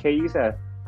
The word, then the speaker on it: Chiesa: I don't know if I'm pronouncing his name wrong. Is it Chiesa? – Yes, I believe he's the best.